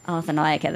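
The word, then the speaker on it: Arthanayake: Go on, pronounce the name, the surname for me. I was getting it wrong. Arthur Nayaka. – Arthur Nayaka. There you go. I have to repeat it after him.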